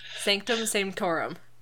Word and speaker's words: Sanctum Sanctorum: I loved Ned nerding out over the shockingly ordinary but also insane basement of the Sanctum, the, how do you say it? – Sanctum The wizard place. – Sanctorum. – Sanctum Sanctorum. I can never remember that.